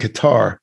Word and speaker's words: Qatar: I used to pronounce that Q-A-T-A-R. I called it Qatar, but it's actually called Qatar. Mm-hmm. That's the proper pronunciation.